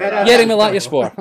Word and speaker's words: Yeni Malatyaspor: That's the worst pronunciation since Jamie called Finn Sparrow's Harry Potter spell. – I forgot. – Yeni Malatyaspor. Uh-huh. Yeah, that's fine. That's fine. Just a wee stumble in the middle.